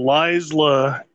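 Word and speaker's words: Laysla: So Lysla, because that's how she pronounces her name, is Lysla de Oliveira, plays Dodge / Well Girl, and she has no titties.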